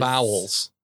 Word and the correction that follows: vows: Not vowels. – Vows? – Vows. Renewal of Vows. – Renewal of Vows. – Yes. – Okay. – Unique. – It's like cows, but with a V.